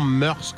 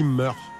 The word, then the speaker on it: mœurs: Le mot mœurs, on dit souvent mœurs, comme je viens de le dire. En fait, ça se dit mœurs.